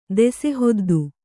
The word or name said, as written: ದೆಸೆಹೊದ್ದು